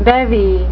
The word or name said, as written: bevy